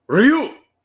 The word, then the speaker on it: Ryu: It's really cool because Ryu, or I'm sorry, the way you're supposed to pronounce his name is Ryu. It's one syllable and it's supposed to be pronounced like that.